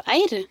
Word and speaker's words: Eyr: Og en ting jeg ble så usikker på var hvordan det her egentlig staves, fordi det uttales jo Eir, men det staves E-Y-R.